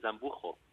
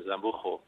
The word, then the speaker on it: Zambujo: Normalmente me dicen Zambujo. Normalmente me dicen Zambujo, pero yo, está bien también, es como se lee aquí en España.